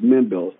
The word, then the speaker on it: Mendel: So it's pronounced Mendel. So it's like a short E at the end.